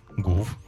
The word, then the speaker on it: Гуф: А вот его как читать? – Гуф. Так и читается. – И Гуф. – Ну не гю, не по-французски.